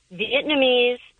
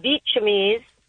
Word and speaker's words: Vietnamese: And then Vietnamese, she says Vietchamese.